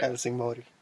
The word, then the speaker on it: Helsingborg: And then we took the boat across to Helsingborg. Am I saying that right? – Helsingborg. – That's how I say it.